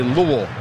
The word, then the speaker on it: Louisville: So the way you say Louisville is you just say it, and then you kind of stop saying it. You say Louisville. You just stop saying Louisville. – It. Louisville. Louisville. Louisville. Kind of like one syllable.